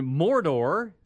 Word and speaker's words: Mordor: There's Vulcan and Mordor.